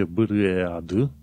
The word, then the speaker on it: bread: Se scrie b r e a d dar tu pronunți bread. Pur și simplu omiți A.